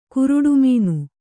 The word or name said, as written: ಗರುಡಿಮನೆ